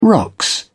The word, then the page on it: rocks: British English